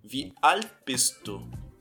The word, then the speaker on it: Wie alt bist du: Se escreve Wie alt bist du? Mas os T's eles vão ser assimilados na fala. Então você vai dizer Wie alt bist du?